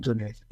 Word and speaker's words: Túnez: Túnez. ¿Es así? Sí, estoy en Túnez.